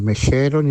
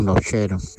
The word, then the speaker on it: Nocheros: Entonces, me gustaría dedicarle esa canción de Mamá de los Nocheros. Esta vez lo he dicho bien. No he dicho ni los mecheros ni los lecheros, sino los nocheros.